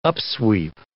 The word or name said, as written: upsweep